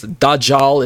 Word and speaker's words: Dajjal: Now, if I'm not saying that name correctly, if you've heard it pronounced like Dajjal or Dajjal or something to that effect, I apologize. Dajjal is how I've heard it pronounced in all the research that I've encountered. So let's read about him.